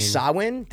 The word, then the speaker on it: Samhain: It's actually Samhain. That's how you pronounce it. You don't pronounce it Samhain.